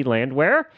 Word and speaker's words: Landwehr: and her name is Christy Landwehr, and I didn't even attempt it earlier.